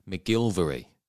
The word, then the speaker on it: McGillivray: Same with Craig McGillivray. The Portsmouth commentators say McGilvery.